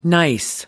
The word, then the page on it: nice: American English